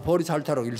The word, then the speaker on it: 보리살타: 보살이라는 건 뭐냐면 보디 사트바 보리살타 우리말로 하면 보리살타라고 읽습니다만은 깨치기는 깨쳤대.